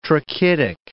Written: trachytic

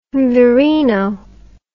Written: virino

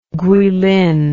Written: Guilin

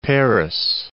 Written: Perris

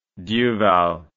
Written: duval

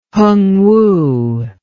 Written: hungwu

